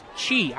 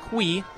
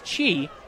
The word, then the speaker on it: qi: I found it's pronounced chi. I thought it was pronounced qui. It's pronounced chi.